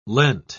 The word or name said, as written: leant